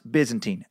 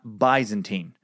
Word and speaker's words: Byzantine: Byzantine is pronounced Byzantine, not Byzantine. I don't know why this bugs the shit out of me, but it just does.